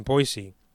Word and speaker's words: Boise: Boise. It is pronounced Boise, not Boise with a Z. It's Boise because it's from the French word for tree.